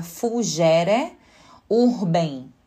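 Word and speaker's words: fugere urbem: Então a gente tem o Fugere Urban, que se vocês fossem escrever seria fugere Urben, com N no final. Uh, em latim se pronuncia Fugere Urben e significa a fuga da cidade ou fugir da cidade.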